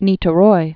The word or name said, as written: Niteroi